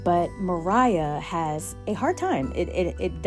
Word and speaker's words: Maria: And by the way, the main character, it's spelled like Maria, but I guess it's Mariah.